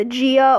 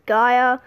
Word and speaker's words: Gaia: Whereas in Heroes of Olympus, we have, depending on where you are, the pronunciation is either Gia or Gaia. I personally pronounce it as Gaia.